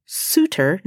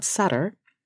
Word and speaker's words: Suter: The story begins in Germany, 1803, when Johann Augustus Suter, spelled with one T and pronounced Suter, not Sutter, is born.